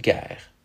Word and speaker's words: guerre: Today, those words are pronounced Gaip and Gair.